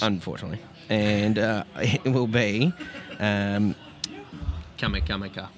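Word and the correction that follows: Kamikamica: however say his name. – Tui Kamakamika. – Kamakamika. Yeah, him.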